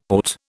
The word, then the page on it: AUT: British English